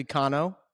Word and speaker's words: Cano: There's a lot of, for my entire 41 years on the planet, my name has been butchered for years, and I never really corrected anybody. It's actually Cano.